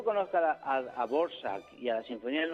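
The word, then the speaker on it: Dvořák: Se escribe Dvorak. – Dvorak. Sí. – Y se pronuncia Borsak.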